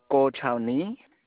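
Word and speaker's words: Guochao Nie: Go Chao Ni. – Could you spell that for me? – The first name is G-U-O-C-H-A-O.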